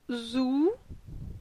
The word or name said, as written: Zou